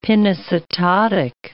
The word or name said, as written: pinocytotic